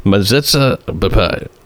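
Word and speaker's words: metzitzah b'peh: Mezitzah bepeh. Am I pronouncing that correctly? – Because I pronounced it so many times. Yeah. Your Yiddish is impeccable.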